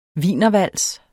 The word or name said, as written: WIENERVALS